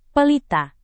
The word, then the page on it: pelita: Indonesian